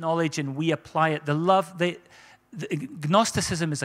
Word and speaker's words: Gnosticism: It's probably a silent G. Gnosticism, all right?